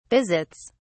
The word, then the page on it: visits: British English